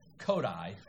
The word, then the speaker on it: Kodály: It's pronounced Kodai. It's written or some variant thereof, but it's not Kodali, and it's not Kodai. You know, it's not Japanese. It's Hungarian.